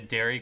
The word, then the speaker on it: Derry: And I do have to say, for legal reasons, we have nothing to do with the Derry Queen franchise. My name is spelled D-E-R-R-Y Queen, not D-A-I-R-Y Queen.